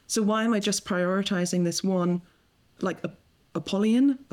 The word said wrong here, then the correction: Apollonian: How would you say that? Apollonian. I got there.